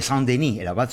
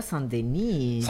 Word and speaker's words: Saint-Denis: Un día como el de hoy, pero en 1144, el abad de Saint-Denis, de París. – Dijo, qué bien pronunciado Saint-Denis.